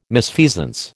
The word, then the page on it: misfeasance: American English